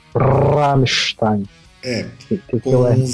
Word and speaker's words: Rammstein: Não, não é, não é Rammstein, é Rammstein. – É, tem, tem razão, tem que puxar CR, eu sempre esqueço no alemão.